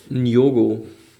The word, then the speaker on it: Nyong'o: Njogo. – Logo, spricht man das ähnlich Njogo. – mit? – Ja, Njogo. – Aha, okay. Lupita Nyong'o.